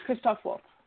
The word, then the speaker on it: Christoph Waltz: They're bringing back they've got Blofeld back as a recurring character played by Christoph Waltz. I think I'm mispronouncing his name. Waltz. Christoph. I promised myself that one of the things that I would learn. Yeah, no, it's Christoph Waltz.